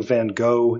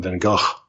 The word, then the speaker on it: Van Gogh: And it's funny that in this episode, that they decided to split it down the middle and call him Van Gogh. – Gotcha. – Only the doctor pronounces it Van Gogh, Van Gogh with an F sound. – Yeah, it sounds like yeah, he's saying Gogh, yes. Yeah. Or G-O-T-H even, it sounds like sometimes, yeah.